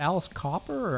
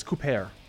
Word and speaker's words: Cooper: Is it Alice Copper or Alice Cooper? – It's Cooper.